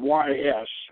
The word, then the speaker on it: Ys: Now, two adjacent seacoast promontories, the prehistoric monoliths of Carnac and the sunken city or castle of Ys, which is spelled Y-S and pronounced Ys.